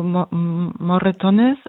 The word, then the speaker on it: Moretones: Ist das die richtige Betonung für Moratona oder habe ich das nicht richtig gesagt? – Also Moretones ist der spanische Titel des Filmes und der heißt auf Deutsch blaue Flecken oder Hämatome oder sowas.